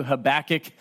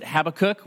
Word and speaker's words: Habakkuk: So if you brought your Bible, you can begin turning to Habakkuk chapter 1. If you pronounce it Habakkuk, we can thumb wrestle after the service and decide who is right.